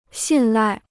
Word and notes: 信赖: xìn lài

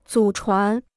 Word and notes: 祖传: zǔ chuán

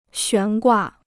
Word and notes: 悬挂: xuán guà

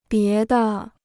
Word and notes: 别的: bié de

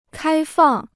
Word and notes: 开放: kāi fàng

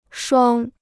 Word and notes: 双: shuāng

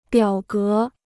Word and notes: 表格: biǎo gé

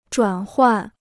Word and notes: 转换: zhuǎn huàn

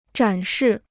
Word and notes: 展示: zhǎn shì

